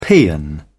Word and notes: paean: British English

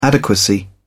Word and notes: adequacy: British English

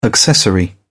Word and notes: accessory: British English

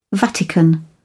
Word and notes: vatican: British English

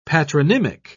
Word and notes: patronymic: British English

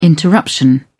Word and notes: interruption: British English

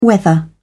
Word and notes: whether: British English

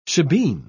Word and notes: shebeen: British English

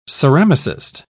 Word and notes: ceramicist: British English

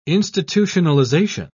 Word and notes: institutionalisation: British English

